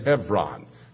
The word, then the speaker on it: Hebron: What is so ironic about that is that Hebron, and maybe you pronounce it Hebron, H-E-B-R-O-N, but in Hebrew the V sound is given to the B, in Hebron,